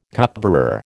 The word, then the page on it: cupbearer: American English